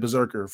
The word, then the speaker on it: BRZRKR: It's spelled BRZRKR, right? Berserker for short.